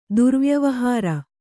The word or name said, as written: ದುರ್ವ್ಯವಹಾರ